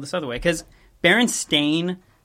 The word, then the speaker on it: Berenstain: I don't know if it's most people, but a lot of people uh, spell it Berenstein, E I N, and also like pronounce it Berenstein Bears. Sorry. sorry. Um, and uh, it turns out that that is not how it's spelled or how it's pronounced. It's Berenstain.